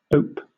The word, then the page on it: ope: British English